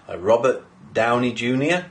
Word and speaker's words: Robert Downey Jr.: So in the latest Iron Man film, Robert Downey Jr., have I said that right? – Yeah.